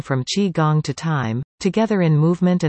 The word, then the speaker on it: Qi Gong: Qi Gong, pronounced Qi Gong, is often mistaken for Tai Chi because both are Far Eastern arts that place an emphasis on movement and breathing.